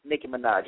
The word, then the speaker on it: Nicki Minaj: And Nicki Minaj. Am I saying it right? Nicki Minaj, yeah. Okay.